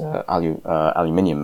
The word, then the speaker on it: aluminium: Even if you look at men like Oleg Deripaska, Deripaska was the tycoon at the head of Rusal, Russia's biggest aluminium manufacturer. For my American listeners, that's how it's pronounced. Aluminium. You lot have been pronouncing it incorrectly for 250 years.